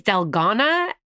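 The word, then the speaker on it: Dalgona: I think it's Delgana. – Delgana. – It's South Korean, yes.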